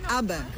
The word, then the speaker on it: Abaim: ABAM. We spell it A-B-A-I-M.